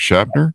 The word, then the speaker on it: Schnaper: Schnapper.